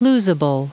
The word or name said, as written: losable